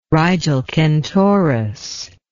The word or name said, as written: Rigel Kentaurus